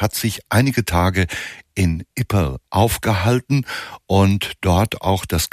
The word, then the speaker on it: Ypres: Die Tindersticks mit einem vergleichsweise stillen, einem Instrumentalalbum mit dem Titel, ich hatte es gesagt, Ypres. Für mich schwierig auszusprechen, denn Ypres eigentlich geschrieben, Ypres ausgesprochen mit einem R, das so unmerklich hinten dran gehängt wird.